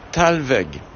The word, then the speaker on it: Thalweg: Un Thalweg.